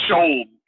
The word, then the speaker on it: Skjold: Yeah, it's pronounced Shold. It's a Nordic name. Swedish.